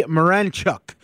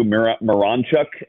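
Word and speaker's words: Miranchuk: They obviously believe in him, and I'm still working on the name too, Marinchuk. I guess the emphasis is on the Ron or in the middle there.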